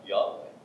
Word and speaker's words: Yahweh: The correct word pronunciation, as you said, is Yahweh.